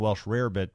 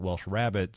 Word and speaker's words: Welsh rarebit: It's always been Welsh rarebit, but somehow when it translated over to America, a lot of Americans were calling it Welsh rabbit.